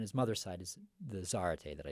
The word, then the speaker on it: Zarate: That was a live performance of the guitarist Raul Garcia Zarate. Did I say it correctly? – Close. Zarate. – Zarate. – Yeah, Zarate.